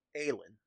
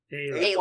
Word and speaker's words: Aelin: Aelin is how I say it? – Aelin. – Aelin. – A-Y-L-I-N is the phonetic pronunciation. – I still might end up calling her big titty goth girlfriend. – A-Y-L-I-N.